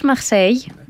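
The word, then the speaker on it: Marseille: Ολυμπιακός Μαρσέιγ. – Ναι. – Εσύ τώρα το λες για τη γαλλική προφορά.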